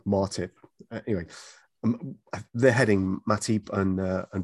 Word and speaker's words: Matip: I think, I mean, the good thing is that in Joel Matip, and by the way, Ola White, if you're listening, it's Matip, not Martip.